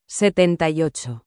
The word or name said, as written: 78